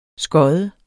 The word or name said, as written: skod